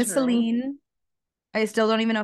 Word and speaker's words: Celine: Oh, I also, in the first book, they never specify this, but in this book, we learn how to pronounce Celine's name properly. And so we have been calling her Selin, which I think everyone calls her Selin because that's how it's written. But she's Turkish and it's apparently pronounced Celine because someone in the book, she like wrote out phonetically like C-E-L-I-N-E. Like someone was like Celine and she was like, oh yes, Celine. like that's my name.